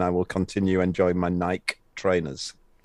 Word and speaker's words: Nike: Funnily enough, um, as an ancient Greek scholar, as I am, or at least was in my university days, the correct pronunciation is actually Nike, because that's the name of the goddess of victory.